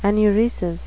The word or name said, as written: enuresis